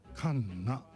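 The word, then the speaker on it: 神無: そして、主題歌がカンナ、神なしと書いてカンナですね、神有月の子供がエンディングテーマ、そして主題歌がカンナ、2曲続けて美和さんの曲いってみましょう。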